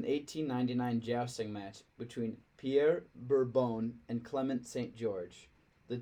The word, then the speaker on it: Bourbon: But at the same time, the French pronunciation was just perfect. Bourbon. Bourbon.